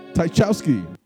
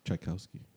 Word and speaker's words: Tchaikovsky: Guy come from Tchaikovsky. Tchaikovsky. Throwing it back. – I don't think you pronounced the T, brother.